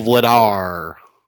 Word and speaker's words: Vladar: but Jacob Markstrom and Daniel Flatter? I don't even know. – Daniel Vladar. – Oh, that's Vladar.